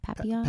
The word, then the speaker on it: Papillon: Papillon. – Papillon. – French for butterfly.